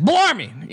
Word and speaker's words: Bellarmine: It's Bellarmine.